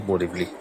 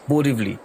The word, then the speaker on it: Borivali: The station was spelled as B-E-R-E-W-L-E-E, Borivali, Berivali, that's how you pronounce it, at about 18.34 miles from Grant Road.